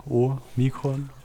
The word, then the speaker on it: Omikron: Genau, ein Omega ist ein langes O und ein Omikron ist ein kurzes O.